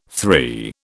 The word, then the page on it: three: British English